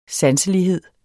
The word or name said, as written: sanselighed